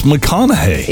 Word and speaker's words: McConaughey: So Matthew McConaughey seems simple enough. – It's McConaughey. – It is McConaughey. A lot of people say McConaughey.